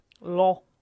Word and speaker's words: Loire: How would you say that? – Loh. – The Loh. Dropped over the Loh in France, and they had to turn back.